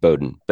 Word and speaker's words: Bowdoin: Pronounced Bowdoin, but yes. – Oh, okay. – Yeah, Bowdoin College.